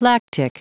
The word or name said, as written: lactic